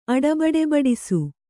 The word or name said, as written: ಅಡಬಡೆಬಡಿಸು